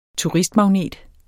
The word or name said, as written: turistmagnet